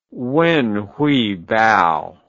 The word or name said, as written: wenhuibao